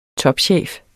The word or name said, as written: topchef